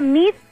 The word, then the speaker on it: Meade: se pronuncia Mid, pero se escribe Meade y el de pandores arroba Grupo Pandora.